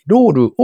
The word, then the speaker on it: LOLLL: スクー移住転職コミュニティロールを提供開始。こちらはプレスリリースで配信されていた記事ですね。株式会社スクーは、いつか地域で働きたい人のための移住転職コミュニティロールを開始したと発表しました。これ綴りが難しいんですけど、 LOLLL でロールと読むそうですね。